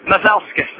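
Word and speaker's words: Masaļskis: Masalakis. Masalakis. Masalskis. Ah. And the accent is on the first syllable.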